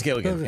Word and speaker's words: Gilligan: Did I say the name right? Vince Gilligan. Yep.